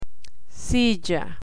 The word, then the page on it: silla: Spanish